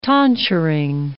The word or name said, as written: tonsuring